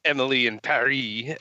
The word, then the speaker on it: Emily in Paris: But my first show is Emily in Paris, or Emily in Paris, as apparently you're supposed to say it because it rhymes.